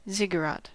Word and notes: ziggurat: American English

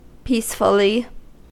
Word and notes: peacefully: American English

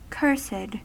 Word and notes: cursed: American English

